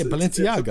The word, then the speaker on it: Balenciaga: Balenciaga, I mean, however you pronounce it. – It's Balenciaga, it's that's how you say it.